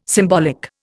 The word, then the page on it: symbolic: Indian